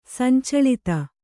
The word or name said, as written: ಸಂಚಳಿತೆ